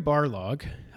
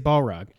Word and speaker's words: Barlog: So, Corey Barlog, I said it right. I didn't say Balrog.